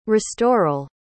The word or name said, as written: restoral